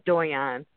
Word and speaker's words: Doyon: It's pronounced Doyon.